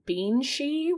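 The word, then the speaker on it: bean sí: So the word banshee comes from the Irish term beanshee, which is, yeah, pronounced just like that. It's like banshee, which translates to woman of the fairy mounds or woman of the mounds.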